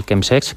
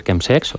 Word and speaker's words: chemsex: que por cierto se puede decir chemsex o se puede decir chemsex. En los, sí, el chemsex, digamos que es el término más inglés, la pronunciación, ¿no? Sí, más anglosajona, ¿no?